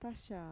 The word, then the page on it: taxaa: Mixtec